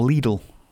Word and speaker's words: Lidl: £1.49 from Lidl, or Lidl, as it's actually pronounced.